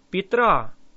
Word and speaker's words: Петра: 1st and 2nd Peter is Первое и второе Петра. Петра. Say ra. Петра.